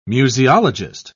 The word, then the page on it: museologist: British English